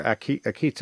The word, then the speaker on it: Akita: So Akita, or Akita, as some may pronounce it, is the Japanese devotion to Mary that has caught a lot of attention.